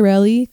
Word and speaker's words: Reli: My name is Reli, but I just go by Reli because apparently pronouncing the A in my name makes it 100 times impossible to say.